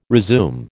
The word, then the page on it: resume: American English